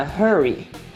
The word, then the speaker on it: hurry: Mas muito cuidado com a pronúncia. Hurry tem um som de R, porque ele começa com H, in a hurry. Mas o outro, ele começa com R, e aí ele não tem um som de R com H, H. Ele tem um R mordido, rush, in a rush, ok?